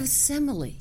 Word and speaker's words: Semele: Now, I wanted to ask, you mentioned this thing about Mack Wellman a minute ago and the son of Simeli. – Son of Semele.